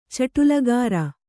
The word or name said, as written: ಚಟುಲಗಾರ